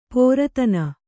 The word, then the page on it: ಪೋರತನ: kannada